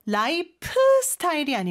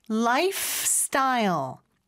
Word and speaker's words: lifestyle: 그 다음에 life 스타일이 아니고 lifestyle. F에서 S로 넘어가는 거. 요거 많이 연습해 주시면 좋을 것 같아요.